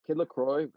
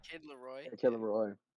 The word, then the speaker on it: Kid Laroi: Kid LaCroix, is that how you say it? – Kid Laroi.